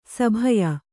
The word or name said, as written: ಸಭಯ